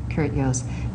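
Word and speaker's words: Jooss: A lot of people see the name in print and don't understand the pronunciation, but it's J-O-O-S-S, Kurt Joost.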